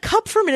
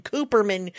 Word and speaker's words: Kupferman: You know, like Kupferman is impossible. Why? I don't know. It is said exactly how it's spelled. But my, like the last 15 years have been Kupferman, Cooperman, whatever.